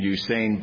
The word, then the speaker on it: Usain: I believe that's the way he pronounces his first name, Usain Bolt.